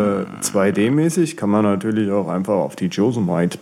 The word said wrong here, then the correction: Yosemite: Beta. Yosemite, jetzt habe ich schon wieder falsch gesagt. – Yosemite, Yosemite Beta updaten.